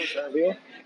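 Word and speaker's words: Xarvio: So tell me a little bit about Zarvio, and am I pronouncing that correctly? – That's correct, yeah, Zarvio.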